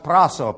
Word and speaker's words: prasso: There's a Greek word in there that is, it's pronounced prasso, P-R-A-S-S-O, and it's similar to the English word practice.